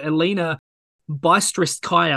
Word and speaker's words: Elina Bystritskaya: And the biggest irony of all of it is that Svitolina is actually named after Elina Bystritskaya, I believe it's pronounced.